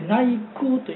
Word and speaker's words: 内宮: はい、私ね、最初にそれ申し上げようって今言っていただいてありがたいんですけどね、伊勢神宮はこの 10月の 2 日に内宮といって、うちの宮と書いて内宮というんでね、内宮って間違うって人が言いますけどね。